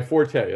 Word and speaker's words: forte: But in French, it's pronounced fort. And in English, it is supposed to be, technically, if you look in the dictionary, the first definition or first pronunciation is the without the E is silent.